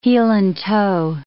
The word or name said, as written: heel-and-toe